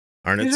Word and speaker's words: Arnott's: Arnott's or Arnott's? What's the pronunciation? Arnott's.